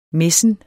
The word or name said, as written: messen